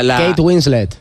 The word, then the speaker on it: Kate Winslet: La Kate Winslet. – Qué bien pronunciado.